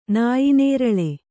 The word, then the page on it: ನಾಯಿನೇರಳೆ: nāyi nēraḷe